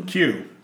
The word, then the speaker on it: queue: Q-U-E-U-E. – Q. – Q. – Yes. – Okay. And what would you say in your head? – Q-E-U-E.